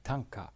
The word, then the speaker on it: Tanka: I don't know his Chinese name, but the Japanese pronunciation of the characters of the master's name is Tanka.